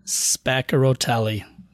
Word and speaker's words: Spaccarotelli: Spaccarotelli. So basically, oh, yeah. John, if you take those C's in the middle and you pronounce them like they're two K's, brother, you got it.